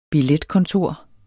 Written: billetkontor

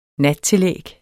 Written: nattillæg

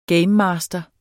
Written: gamemaster

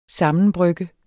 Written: sammenbrygge

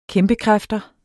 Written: kæmpekræfter

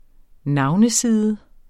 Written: navneside

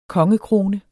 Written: kongekrone

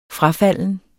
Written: frafalden

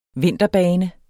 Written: vinterbane

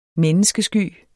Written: menneskesky

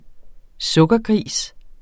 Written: sukkergris

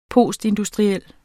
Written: postindustriel

